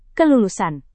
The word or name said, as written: kelulusan